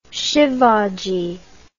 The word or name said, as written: Sivaji